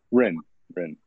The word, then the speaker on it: Hryn: Did I pronounce that right? – Hryn.